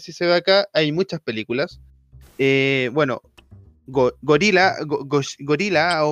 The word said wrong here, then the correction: Gojira: Gojira, Gojira, ahí está.